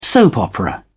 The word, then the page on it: soap-opera: British English